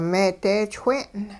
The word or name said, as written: meedeechwe'n